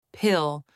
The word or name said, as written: pill